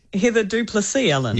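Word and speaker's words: Heather du Plessis-Allen: Heather Duplessis-Allen.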